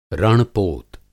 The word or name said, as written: रण-पोत